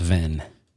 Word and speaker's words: Venn: And I forget the name of this kind of diagram, but it's pronounced Venn.